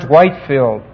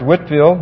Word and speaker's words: Whitefield: The last man is George Whitefield, often mispronounced George Whitfield and misspelled, and he is from Angleland, and he was an Anglican.